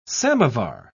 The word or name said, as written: samovar